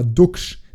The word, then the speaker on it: Duksch: Weil es gibt, also der Niklas mit K ist schon gewöhnlicher als Niklas mit C, aber Duksch, der ja offensichtlich Ducken und nicht Duken ausgesprochen wird,